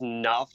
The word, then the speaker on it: Noff: it's Noff, just like N O F F.